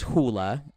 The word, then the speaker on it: Hula: Well, a lot of people, and, you know, my name's Hula, like the dance. Um, a lot of people do say Hulu um, a lot of times because it's like the streaming service.